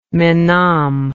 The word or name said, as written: menam